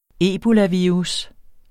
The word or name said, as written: ebolavirus